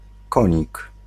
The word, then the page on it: knight: /naɪt/; [naɪt]